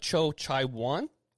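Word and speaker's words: Cho Jae-won: Okay, so I'm going to say this name slowly. And my sister-in-law, Anna, actually lived in South Korea and knows some of the language, helped me say this name. So if I say it wrong, it's still my fault. But she did help me. It's Cho Chai-won.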